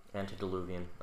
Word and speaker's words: antediluvian: How do you say that? – Antediluvian? – Antediluvian. Okay.